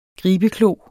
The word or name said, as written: gribeklo